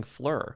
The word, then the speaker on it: Phlur: So I should probably start using Fleur. – Oh, wow. – Because Fleur is luxurious perfume that's all about good, clean, fun. And it's spelled P-H-L-U-R, which we know, you know, we love a pun.